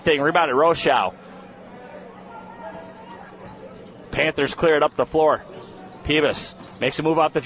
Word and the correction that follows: Pybus: They're saying it's Pybus. I'm saying it wrong.